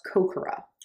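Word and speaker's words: Kokoro (心): But I believe it's pronounced Kokoro. – Okay. Okay. – So it's spelled K-O-K-O-R-O.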